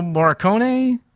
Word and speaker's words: Morricone: We talked about the musical score by, how do you pronounce his name? You're better at this than me. Morricone? – I believe that's how you say it, yeah.